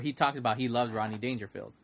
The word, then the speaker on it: Rodney Dangerfield: And I don't know, I feel like Ronnie without the D, huh? – Oh, I said it fast. Ronnie Dangerfield. Ronnie. I say it fast. I have to pronounce it. – And your dad loves Rodney Dangerfield, because I remember he talked about that.